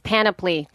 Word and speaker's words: Panoply: So basically what this is all the big boy networks, NPR, Panoply, Wondery, Time, Podcast One. – Dude, it's Panoply. Just fuck, I say that wrong every time. I like Panoply, but okay, yeah. Panoply. Yeah.